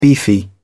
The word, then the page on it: beefy: British English